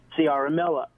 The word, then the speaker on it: Ciaramella: and it's Eric Ciaramella.